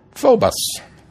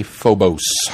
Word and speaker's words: Phobos: Phobos, which I keep wanting to say Phobos. And it took me listening to it again to go, oh, no, I've been pronouncing that wrong.